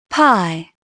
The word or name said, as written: pie